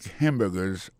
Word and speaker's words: hamburger: N'a pas remarqué que dans le mot hamburger, ça a comme tu as dit, il y a trois syllabes là-dedans. Quand tu appuies et puis ça, c'est sur le premier syllabe là.